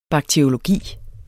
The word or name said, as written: bakteriologi